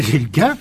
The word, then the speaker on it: Gilkin: C'est-à-dire Yvan Gilkin, je le prononce peut-être mal. – Gilkin, à la française.